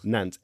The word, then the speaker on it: Nantes: Nantes, N-A-N-T-E-S, yeah. – N-A-N-T-E-S. – I always presumed it was Nantes, but people were pronouncing it as Nantes. So Nantes. – It's not Nantes Watch. Stop it. Stop it.